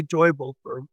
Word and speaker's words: Teufelsberg: And you mentioned, now I'm not sure on the pronunciation, is it Teufelsberg, the Sigint site? – Teufelsberg. Devil's Mountain.